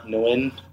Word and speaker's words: Nguyen: That's at Andy the Nguyen or Andy the Win, whatever.